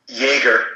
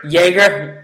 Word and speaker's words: Jaeger: Dave Jorger's entering year two. Their offensive rim... It was. Did I say his name really wrong? – Jaeger. – Jaeger, like the drink.